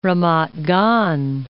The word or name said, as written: Ramat Gan